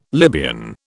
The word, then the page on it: Libyan: British English